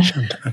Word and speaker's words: Shantaram: Shantaram.